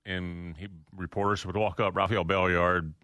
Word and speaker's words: Rafael Belliard: I'm going to go with Rafael Belliard. – There you go, little Rafi. – You pronounced it right.